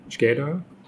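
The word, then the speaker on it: Shkodër: es ist Tag 553 ähm, sind in Skoda, Skeda. Ah, albanische Aussprache.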